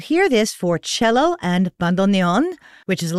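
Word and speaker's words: bandoneón: And in order to make it easier for non-Spanish speakers to pronounce, he very graciously invites you to say bandoneon. Bandoneon. There you go.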